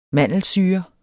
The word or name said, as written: mandelsyre